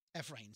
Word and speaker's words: Ephraim: F-ra, even though it looks like E-F and the word rain. Ephraim.